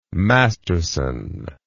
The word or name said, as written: masterson